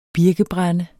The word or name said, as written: birkebrænde